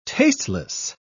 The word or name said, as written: tasteless